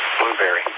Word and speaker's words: Blubrry: There is a service called Blueberry, and that's spelled B-L-U-B-R-R-Y, Blueberry. You can still get a podcast called The Doctor Is In.